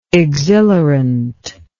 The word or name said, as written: exhilarant